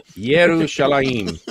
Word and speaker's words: Yerushalayim: Yerushalayim. That's how you say it.